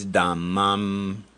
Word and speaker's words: Dhammam: And it's Dhammam Saranam Gacchami. It's a long A.